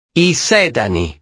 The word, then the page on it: i sedani: italiano